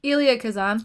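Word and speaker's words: Elia Kazan: Ilya Kazan.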